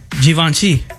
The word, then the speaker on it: Givenchy: Givenchy. – I've never even heard of that brand. – You're supposed to pronounce it with a Z, according to this. It's Z-H-E-E-V-O-N-S-H-E-E. Givenchy.